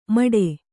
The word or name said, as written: ಮಡೆ